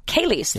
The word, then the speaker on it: Cayley's: Just in case wine wasn't your thing, Stella Artois is doing a competition on this Thursday, July 9th at the, I think it's pronounced, is it Chili's Irish Pub? – Cayley's. – Thank you very much.